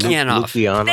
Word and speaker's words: Lukianoff: Lukianoff.